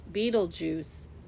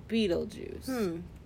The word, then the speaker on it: Betelgeuse: Beetlejuice. It's called Beetlejuice! – Oh. – but it's spelled B-E-T-E-L. G-E-U-S-E. Pronounced Beetlejuice.